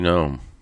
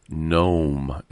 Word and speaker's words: Noem: Kristi Noem. Noem, it's pronounced.